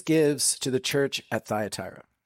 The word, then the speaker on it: Thyatira: Technically in Greek, it's pronounced Thuatira, but we'll call it Thyatira in honor of my southern grandparents.